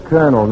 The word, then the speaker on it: colonel: Well, sometimes the O-L is pronounced all. But when it's in relation to the Army, the O-L is pronounced E-R, like in colonel.